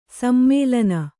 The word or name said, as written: ಸಮ್ಮೇಲನ